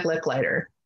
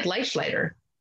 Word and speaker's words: Licklider: And we just had a conversation about how to say that name because it doesn't look like Licklider. It looks like Leichleiter or Leichleiter. – And if we were in Germany, that would probably be the way to be pronounced.